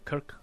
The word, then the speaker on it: Krk: It's named KRK, Kirk Island, KRK, that's how it's spelled.